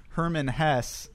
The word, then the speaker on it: Hermann Hesse: We are reading Steppenwolf by Hermann Hesse, is how I looked up that that's pronounced.